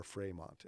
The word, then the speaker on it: Fremont: Fremont. And he was, or Fremont as he pronounced it.